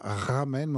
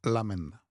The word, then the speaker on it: ramen: Alors, à la japonaise, on ne prononce pas ramen, mais on prononce lamen.